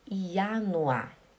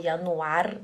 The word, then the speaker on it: Januar: I-A-N-U-A. Esse R no final do A, no final da palavra, a gente não pronuncia, a gente fala Januar. Januar, né? Não é Januar ou Januar, né? É Januar. Januar.